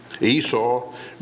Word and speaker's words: Esau: Esau grew to look like the guy in charge, manly and ruddy and hairy. So hairy, they named him Harry, or in the mother tongue, Esau, which rhymes with seesaw, which is an apt name for a man who certainly saw his share of ups and downs.